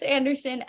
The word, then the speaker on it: Anderson: Liss Anderson, L-Y-I-S-S-A, and then Anderson, S-O-N at the end.